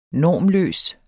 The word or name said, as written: normløs